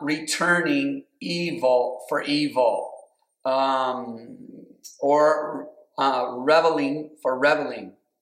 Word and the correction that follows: reviling: or reviling. I think you should pronounce it reviling.